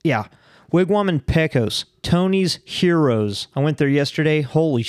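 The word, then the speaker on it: gyros: But you remember when I was promoting Arby's to, they're pronounced heroes for $6? – Euro.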